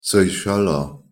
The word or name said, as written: seycheller